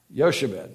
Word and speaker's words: Jochebed: Say it again. Yochebed. Yochebed. J-O-C-H-E-B-E-D. Is that how you pronounce it, Wayne? Oh. That's cheating. The Hebrew. You're giving us a good Hebrew. Okay. If you say that or Jochebed or something like that, you get your donut this morning.